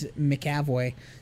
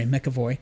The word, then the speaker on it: McAvoy: And in an interview, James McAvoy said... Sorry, McAvoy. James McAvoy said...